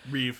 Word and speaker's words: Reeve: Reeve.